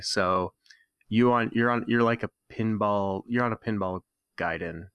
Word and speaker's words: Gaiden: Now, proper pronunciation, if you want to be pretentious, is Ninja Gaiden, but it's, you know, everyone called it Ninja Gaiden growing up.